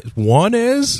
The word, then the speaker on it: Jaunes: Jaunes? – That's what I heard. I went on YouTube to watch pronunciations on this, and that's what they said, man. – Gilets? – Sounds just like that.